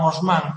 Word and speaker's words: Haussmann: Bueno, le llaman Osman porque lo pronuncian a la francesa, pero es Habsburgo, el apellido es de origen alemán.